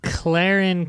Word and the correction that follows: Ciaran: Oh no, how do you pronounce this? C I A R A N Campbell. – Kieran.